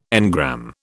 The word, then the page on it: engram: British English